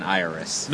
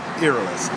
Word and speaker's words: Iroas: I'm saying Iris. – No, I have the pronunciation guide for wizards. It's Iroas.